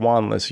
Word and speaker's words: Wanless: And Harold Wanless, who I mispronounced his name earlier, but Harold Wanless, University of Miami,